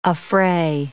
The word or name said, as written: affray